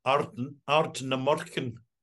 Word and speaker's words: Ardnamurchan: Let me give you the Scottish version. Ardnamurchan. – Ardnamurchan. – You gotta swallow the R.